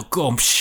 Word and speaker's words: Gomes: Gomes.